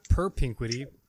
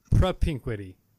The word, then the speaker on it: propinquity: How do you say this word again? Propinquity. – Not perpinquity. Propinquity.